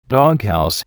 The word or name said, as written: doghouse